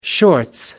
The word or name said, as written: shorts